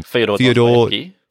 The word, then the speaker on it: Fyodor Dostoevsky: Fyodor Dostoevsky.